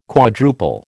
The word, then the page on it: quadruple: American English